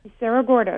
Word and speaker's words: Cerro Gordo: Cerro Gordo, yes.